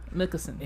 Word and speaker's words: Mikkelsen: Yeah, Mikkelsen.